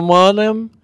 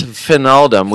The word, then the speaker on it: Wijnaldum: Jorginho Wijnaldum? Wijnaldum. – Hey. – It's Wijnaldum.